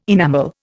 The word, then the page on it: enamel: Indian